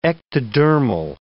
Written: ectodermal

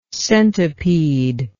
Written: centipedal

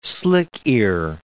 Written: slick-ear

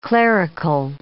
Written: clerical

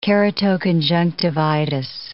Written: keratoconjunctivitis

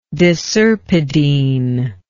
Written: deserpidine

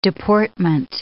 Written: deportment

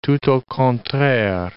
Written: tout au contraire

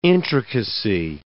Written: intricacy

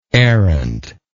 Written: errantly

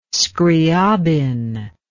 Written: Scriabin

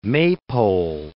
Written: maypole

